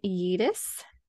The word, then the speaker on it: Juedes: So it's about a man named Ken Yeatus. So I made sure I wrote down how to pronounce the name because it's spelled J-U-E-D-E-S. So, but it's pronounced Yeatus. – Yeatus.